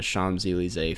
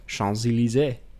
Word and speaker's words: Champs-Élysées: That was, if anyone was curious, that was from a talk he did at the Champs-Élysées Film Festival, which I didn't. – Wow, such good French pronunciation. – Champs-Élysées. Yep. It's barely, it's not that good.